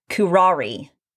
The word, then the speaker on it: curare: So the poison that was inside the bolt was filled with, I'm going to have to pronounce this, curare. Okay? It's spelled C-U-R-A-R-E.